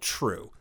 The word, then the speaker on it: TRVE: And so I looked up my favorite Denver brewery, which is this brewery called True. It's spelled T-R-V-E.